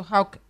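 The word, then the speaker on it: Hawke: Hawk.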